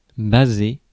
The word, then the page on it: baser: [ba.ze]; Paris